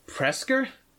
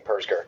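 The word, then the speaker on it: Presger: You mean the Presker? – I'm pretty sure it's P-E-R-S. Or maybe is it... – It's... Okay, the audiobook says it like Presca. – Let me... – Like rhymes with Fresca.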